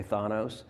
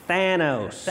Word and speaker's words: Thanos: Someone pointed out, apparently I pronounce his name wrong because I say Thanos. – And I How guess is it pronounced? – It's Thanos.